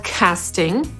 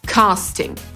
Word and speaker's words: casting: Tutaj usłyszeliśmy wymowę casting, czyli wersję amerykańską, mimo że wypowiada to słowo brytyjska aktorka, ale ona akurat w tym filmie gra Amerykankę, więc mówi z amerykańskim akcentem. Po brytyjsku, czyli w swoim akcencie, powiedziałaby casting.